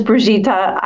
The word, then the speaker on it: Brigitta: My name is Brigitta. I think I am literally the only Brigitta in our states, at least with that pronunciation.